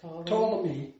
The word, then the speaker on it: Ptolemy: Ptolemy. – Ptolemy? – I don't think you pronounce the P. – The P is silent? – Yeah.